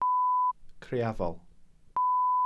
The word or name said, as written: criafol